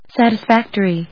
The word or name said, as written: satisfactory